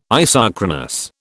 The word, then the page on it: isochronous: British English